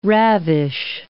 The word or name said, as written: ravish